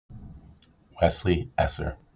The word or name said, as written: Wes Esser